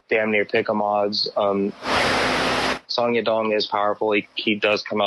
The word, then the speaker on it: Song Yadong: I believe he's pronounced Song. You've been pronouncing it right. Song Yadong is what he gets called on the UFC broadcast.